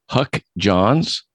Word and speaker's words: Huck Johns: My guest today is Huck Johns.